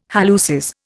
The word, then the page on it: halluces: Indian